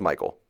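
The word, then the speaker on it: Mikael: My Red Light District this week, I have – it's pronounced Michael. Michael, right? Michael Backlund, but it's spelled McHale.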